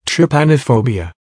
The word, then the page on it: trypanophobia: American English